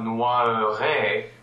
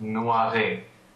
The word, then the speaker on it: noierai: And in this case, the word is not noiré with the schwa actually pronounced. It's just noiré.